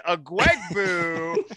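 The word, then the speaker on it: Ugwoegbu: David Ugawogabu. – That's not even close. David Agwegbu.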